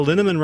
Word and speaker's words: Linneman: Val Linneman rhymes with Cinnamon.